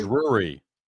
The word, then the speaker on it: Drury: How do you say it? Drury? – Drury. Brandon Drury.